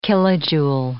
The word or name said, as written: kilojoule